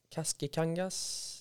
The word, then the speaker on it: Kaskikangas: Isa Tidblad, Kaskikangas. Du uttalar hennes efternamn jättebra.